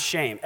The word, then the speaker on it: shem: It's pronounced shame, S-H-E-M. Noah's son, who we call Shem, his name was pronounced shame.